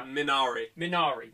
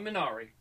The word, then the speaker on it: Minari: So we have The Five Bloods, Mank, how are we going to pronounce this now? – Minari. – We're going Minari.